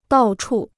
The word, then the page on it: 到处: dào chù